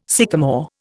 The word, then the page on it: sycamore: Indian